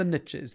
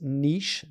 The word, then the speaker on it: niche: For everybody listening, it's pronounced niche, not niche. – Who says niche? It's niche. – No, but a lot of Americans say niche. And the reason why it's niche, and I'll explain if you don't mind just for a second. Yeah. It's a French word, because I'm half French.